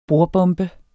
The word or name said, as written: bordbombe